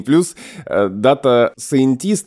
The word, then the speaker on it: scientist: Слово это предлагается писать через дефис и вот именно в формате data не data, а data А есть отдельно слово scientist, именно с таким ударением на и. Scientist. После А буквы и краткая, дальше Е, дальше Н.